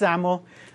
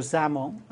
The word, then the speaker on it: XAML: And it's pronounced XAML. Kind of sounds like you're pronouncing with a Z. So XAML. And all it is a serialization and initialization format.